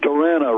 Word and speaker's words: Durana: That is Joe Durana, because he kept saying, my name's Durana, rhymes with banana.